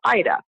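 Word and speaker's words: Ayda: Well, my name is spelled a little differently. It's A as in apple, Y as in yes, D as in dog, A as in apple. But it's pronounced Ida.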